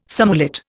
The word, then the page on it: surmullet: Indian